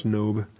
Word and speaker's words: Nob: Nob's a town. It's probably pronounced Nob. Yes, it's Nob.